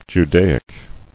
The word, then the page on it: Judaically: Ju*da"ic*al*ly